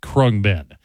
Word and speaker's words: Khruangbin: And go ahead and bust out a pencil if you liked what you heard, because they are spelled K-H-R-U-A-N-G-B-I-N. It's pronounced Krungbin.